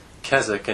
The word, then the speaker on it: Keswick: Keswick, and you're actually pronouncing it correctly. That's good. It's spelled K-E-S-W-I-C-K, so the W is silent.